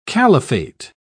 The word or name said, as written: caliphate